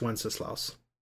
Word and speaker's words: Wenceslaus: So, also, I don't even know if it's pronounced Wenceslaus. Is that how you pronounce it? – Yes, Wenceslaus.